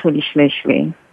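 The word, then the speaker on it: Rolihlahla: It is pronounced Holishleshle.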